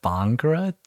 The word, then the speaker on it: Bhangra: I heard from a friend that you were part of the, I can't pronounce this, but the Bhangra dance team? – Yeah, you pronounced it correctly.